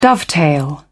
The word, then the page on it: dovetail: British English